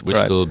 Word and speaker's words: WSDL: Yeah, it's part of being a member of the community or sharing the secret key, like using these funny pronunciations of the standards, whistle, right.